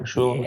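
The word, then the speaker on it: Mechoulam: Did I say that right, Meshulam? – Yeah.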